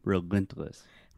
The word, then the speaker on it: relentless: Yes, the stress is on the middle syllable, relentless.